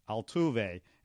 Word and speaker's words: Altuve: I guess I have to say this three syllables before we close things out Altuve, Altuve. And by the way, I, I said it right that time.